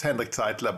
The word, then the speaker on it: Hendrik Zeitler: But my name, it's no problem because in Sweden, everybody thinks my name is Hendrik and nobody can pronounce my last name. It's Hendrik Zeitler, but you can say whatever you want.